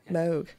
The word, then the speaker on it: Moog: By Bob Moog. So that's, or Bob Moog, as the Americans say, which is that, I know. Bob Moog, let's go with, because that's how he called himself. – Someone once told me it's actually something like Moog.